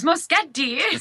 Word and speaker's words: Moschetti: This old Italian woman started yelling at me, why do you say your own name, your own last name wrong? I'm like, what? She says, it's Moschetti.